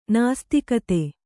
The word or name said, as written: ನಾಸ್ತಿಕತೆ